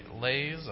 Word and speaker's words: lays: A lay is a song, and it rhymes with rains, right? Lays and praise. Rhymes with praise, doesn't rhyme with rain.